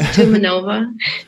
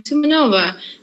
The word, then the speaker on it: Tumanova: I know my last name looks long in Russian, but it's actually not. It's easy to pronounce. Tumanova.